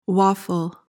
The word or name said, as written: waffle